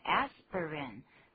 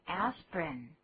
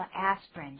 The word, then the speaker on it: aspirin: Excuse me, I was just going to say, aspirin, 本来是有三个音节的 aspirin, 可是我们现在大部分只念成两个音节 aspirin, 第一个 I 根本没有念出来念作 aspirin 就好了。